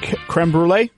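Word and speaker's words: crème brûlée: and creme brulee is how it's pronounced.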